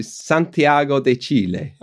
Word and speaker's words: Santiago de Chile: Yeah, I went to Santiago de Chile. – Okay, perfect pronunciation there.